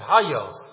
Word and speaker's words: Hagios: The Greek word for holy is Hagios. Actually, the Greeks pronounce it Hagios, but the three holy ascriptions to God.